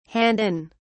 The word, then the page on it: hand in: British English